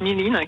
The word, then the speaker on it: mirin: Alors en français, on va le lire mirin, mais en japonais, ça se prononce mirin, qui est un saké légèrement sucré mhm.